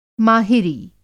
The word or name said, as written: माहिरी